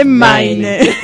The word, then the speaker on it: Maine: Se escribe Maine, se lee Maine.